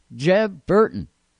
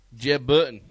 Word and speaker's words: Jeb Burton: Jeb Burton. – You said it wrong. Jeb Burton.